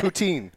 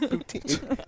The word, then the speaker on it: poutine: Putin. Putin. No, poutine. – No, it's Putin. – It's spelled Putin. – I think it's Putin. – It's poutine. It's poutine. It's French fries and gravy with some cheese curds.